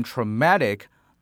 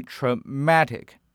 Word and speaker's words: traumatic: In trauma, the stress is on the first syllable, tra, yet in traumatic, the stress should be put on the second syllable, ma. So it should be traumatic instead of traumatic.